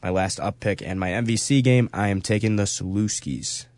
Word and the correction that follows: Salukis: Saluskis? How do you say that? – Salukis. – Salukis. I always say it wrong.